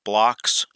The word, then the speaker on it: Bloch: If you want to see where Gygax probably would have taken Second Edition, you have to look no further than Joseph Bloch's,